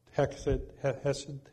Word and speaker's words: Hesed: He, you know, is Hek, I think it's pronounced Hesed,